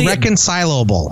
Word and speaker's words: irreconcilable: I say irreconcilable. It's the double L sounds weird, but I'm saying it right.